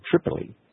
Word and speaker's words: Tripoli: How do you say that, Mike? Tripoli. – Mm-hmm.